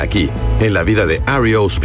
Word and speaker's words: REO: Al principio, sus miembros le decían Rio, pero con el fin de diferenciarse de la marca de camiones, decidieron usarlo en forma de iniciales, lo que dejó el grupo como REO Speedwagon y como lo estamos pronunciando correctamente aquí en el programa.